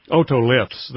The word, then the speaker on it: otoliths: You say you cut open the otoliths, is that how you pronounce it? – Otoliths, that means ear stone.